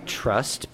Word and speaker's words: TRST: There is a band called Trust, but that's spelled T-R-S-T.